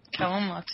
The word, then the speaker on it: Kellan Lutz: In the epic origin story, The Legend of Hercules, Kellan Lutz, did I say that right? – Kellan Lutz, yeah.